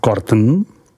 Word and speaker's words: Corton: Corton, vyslov Corton, Corton Charlemagne, vyslovuj Corton Charlemagne, Charlemagne, vyslovuj Charlemagne.